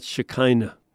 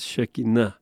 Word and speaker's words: Shekinah: What a pronunciation. It's not Shekinah. That would be spelled very differently. It's Shekinah. And it's a word that's not in the Bible.